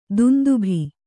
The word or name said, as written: ದುಂದುಭಿ